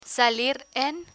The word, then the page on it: salir en: spanish